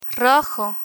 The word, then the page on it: rojo: spanish